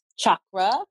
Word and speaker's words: chakra: It's, I believe the word chakra in itself, which it's pronounced chakra. It's an ancient Sanskrit word.